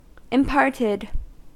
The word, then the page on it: imparted: American English